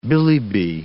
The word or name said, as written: billi-bi